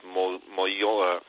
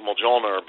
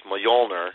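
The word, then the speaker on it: Mjölnir: His hammer, I think, is pronounced Mjolnir. For years I've pronounced it Mjolnir, but it's Mjolnir.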